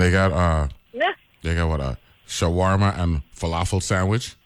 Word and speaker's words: falafel: My moons falafel.